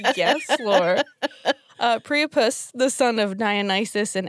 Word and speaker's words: Priapus: I don't know how Priapus? – To pronounce it. – As like platypus? – Usual. Yes. Minus the pla. – Minus the bill. – Yeah. It's Priapus.